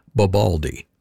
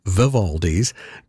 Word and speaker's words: Vivaldi: A longtime colleague and friend of mine quite seriously pronounces that composer's name Bobaldi, B as in bald, and wonders why everyone chuckles when he expresses his affection for the music. We heard Antonio Vivaldi's Concerto for Two Mandolins.